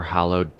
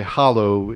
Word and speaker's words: hallow: Well, I pronounce it hallow. I can't claim that that's the absolute universal pronunciation of that word. But if I say hollow, it implies I'm carving something out from the middle or something.